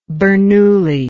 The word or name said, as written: Bernoullian